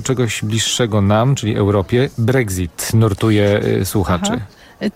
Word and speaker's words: Brexit: I teraz jeśli chodzi o Brexit, bo ja jednak chyba bym wymawiała tutaj ksy, a nie gzy, czyli Brexit, było pytanie o to, dlaczego Brexit piszemy wielką literą.